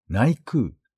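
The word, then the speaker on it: 内宮: さらに、外のお宮と書いて下空、内側のお宮と書いて内空とに分かれています。 1500 年の歴史がある下空、正式には豊受大神宮、衣食住をはじめすべての産業の守り神と言われています。 2000 年の歴史がある内空は、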